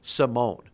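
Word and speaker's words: Simón: Alfredo Simone, which, by the way, that's the pronunciation of his last name, Simone. How many times have I heard, not just in spring training, but during the season as we started, everybody is pronouncing his name Alfredo Simon. That's not the way his name is pronounced. Get it right. It's Alfredo Simone.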